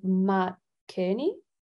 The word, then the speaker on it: Matt Kearney: Matt Kearney.